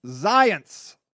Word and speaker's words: Zients: Here, if you want to be in the cool, I'll tell you how to pronounce this. Zients. It rhymes with science.